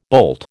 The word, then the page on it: boult: American English